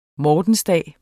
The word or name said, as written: mortensdag